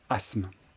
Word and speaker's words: asthme: Asthme s'écrit A-S-T-H-M-E. Asthme. Et on retient aussi le mot asthmatique. Asthme. Asthmatique. Ici, la difficulté est que l'on ne prononce pas le T. Asthme. Asthmatique. Vous l'aviez?